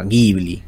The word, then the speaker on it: Ghibli: la cuñan de esa forma, Ghibli, así es como deberíamos pronunciarla.